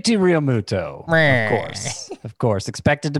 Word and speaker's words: Realmuto: I got to train myself to say it differently because I heard him say it himself and he said Realmuto. So despite some of the pronunciation guides online, going straight from the horse's mouth on that, JT Realmuto.